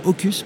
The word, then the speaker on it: Hokus: Euh, est-ce que vous pouvez en deux mots nous présenter The Hocus Platform Ça s'écrit, je le dis parce que bien sûr on va vous chercher, H-O-K-U-S. – Exactement, H-O-K-U-S, comme Hocus Pocus pour les bons lecteurs de...